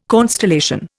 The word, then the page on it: constellation: Indian